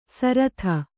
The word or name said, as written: ಸರಥ